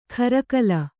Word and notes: ಖರಕಲ: kharakala

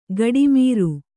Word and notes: ಗಡಿಮೀರು: gaḍimīru

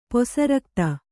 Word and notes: ಪೊಸರಕ್ತ: posa rakta

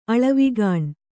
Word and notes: ಅಳವಿಗಾಣ್: kannada

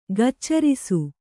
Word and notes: ಗೋಚರಿಸು: gōcarisu

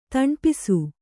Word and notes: ತಣ್ಪಿಸು: taṇpisu

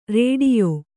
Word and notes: ರೇಡಿಯೋ: rēḍiyo

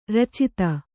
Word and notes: ರಚಿತ: racita

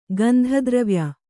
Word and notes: ಗಂಧದ್ರವ್ಯ: Kannada

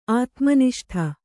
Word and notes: ಆತ್ಮನಿಷ್ಠ: ātmaniṣṭha

